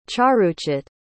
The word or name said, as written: charuchit